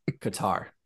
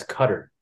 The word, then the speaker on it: Qatar: Qatar. Good job. I'm so proud of you. Nailed it. Last week we talked about it and he pronounced it as cutter. Guys, cutter.